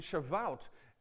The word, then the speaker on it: Shavuot: And Shavuot, rhyming with wheat, was the celebration.